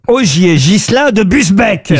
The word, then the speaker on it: Busbecq: Augier Gillin de Busbecq, pardon, j'ai écorché son nom. – Il semble bien aussi. – Ça, c'est pour ça, oui. – Est-ce que vous pouvez appeler Busbecq? – B-U-S-B-E-C-Q. Olivier, pardon, Augier Gislin de Busbecq.